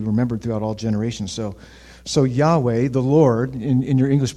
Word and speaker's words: Yahweh: So his name, in the Hebrew, the closest pronunciation we can come up with is Yahweh.